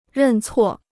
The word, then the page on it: 认错: rèn cuò